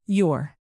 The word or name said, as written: youre